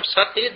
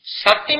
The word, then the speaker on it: sati: When you write it, it is the same. S-A-T-I, sati. But when you pronounce, there is a difference. One word is sati, the other is sati. Sati and sati.